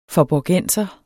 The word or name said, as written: faaborgenser